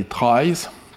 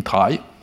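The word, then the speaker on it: trie: Il semble que ça vient de information retrieval, sauf que dans ce cas-là, ça devrait se prononcer tri, mais tout le monde dit try.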